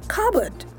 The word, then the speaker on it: cupboard: Of course, the P is silent. So all you need to say is cupboard. Cupboard, not cupboard. Cupboard. All you need to do, forget about the P because it's silent.